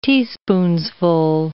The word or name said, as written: teaspoonsful